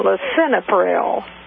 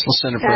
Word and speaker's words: lisinopril: and she was on lisinopril. I believe that's the way it's pronounced. – It is, lisinopril.